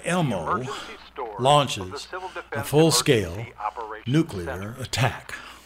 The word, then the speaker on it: LMOE: Elmo. L-M-O-E. – L-M-O-E. – Pronounced Elmo. – Civil Defense Audio Instruction Series, Disc L-M-O-E-A.